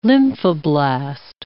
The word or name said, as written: lymphoblast